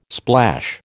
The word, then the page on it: splash: American English